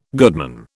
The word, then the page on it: goodman: British English